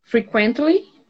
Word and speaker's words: frequently: Frequently. – Okay, so the emphasis is in the first one, frequently.